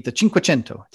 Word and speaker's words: Cinquecento: And for all the money that you say, so imagine that the Cinquecento, did I say that right? – Wow. – I am impressed. – I know, right? Yeah, that's right.